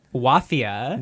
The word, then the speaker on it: Wafia: Wafia. – Which rhymes with mafia. – Rhymes with mafia, spelled like mafia, but with a W.